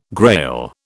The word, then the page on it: Grail: British English